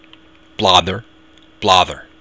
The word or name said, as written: bladar